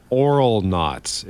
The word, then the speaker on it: Auralnauts: There's a channel on YouTube called Oral Knots. It's spelled AURALNAUTS, and they basically do overdub things, or they dub new voices over movie scenes.